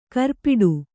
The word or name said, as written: ಖುರಪುಟ